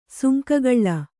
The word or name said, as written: ಸುಂಕಗಳ್ಳ